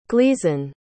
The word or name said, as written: gleeson